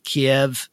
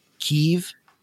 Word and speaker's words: Kyiv: It's funny you should say that so everybody, if you were, if you thought you were losing your mind when news media started calling Kiev Kyiv, uh, that was intentional. That's because uh, Kiev is the Russian pronunciation.